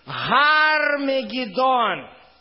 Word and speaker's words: Гармагеддон: И он собрал их на место, называемое по-еврейски Гармагеддон. Я пишу «Г», потому что в русском тоже нету различия между «Г» и «Г», но это произносится как «Г», украинское.